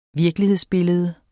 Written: virkelighedsbillede

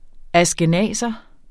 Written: ashkenazer

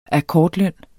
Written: akkordløn

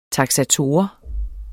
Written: taksator

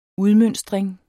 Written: udmønstring

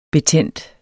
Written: betændt

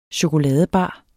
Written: chokoladebar

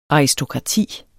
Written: ARISTOKRATIER

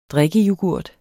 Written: drikkeyoghurt

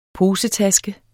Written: posetaske

